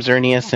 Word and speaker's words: Xerneas: Definitely the two legendaries, and I'm going to mispronounce them, Xerneas and Yveltal.